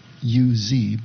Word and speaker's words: Uz: He is said to come from the land of Uz, and it's going to be spelled U-Z, but it's pronounced Uz.